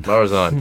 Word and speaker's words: Marazion: is it Marazion? – Marazion, thank you, I'm awful with Cornish pronunciation.